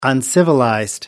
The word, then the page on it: uncivilized: American English